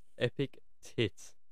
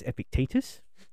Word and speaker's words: Epictetus: It's Epictetus. It is ye old Latin and Greek. So it's pronounced differently. And it's Epictetus.